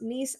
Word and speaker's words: Nice: But anyway, yeah, Nice is in France and it's pronounced Nice, not nice.